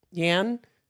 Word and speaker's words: Yan: Yan. Y-A-N. – Yan. I don't know. I'm sorry. – Yan. – You're on the paper. – Look, it sounds like it looks like Yan, but with an N.